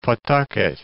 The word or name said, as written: Pawtucket